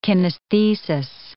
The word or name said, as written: kinesthesis